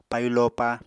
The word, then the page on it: payu lopa: Apatani